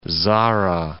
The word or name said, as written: Zara